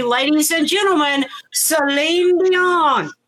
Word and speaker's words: Celine Dion: It is Celine Dion. That's her name in French. That's how you say her name.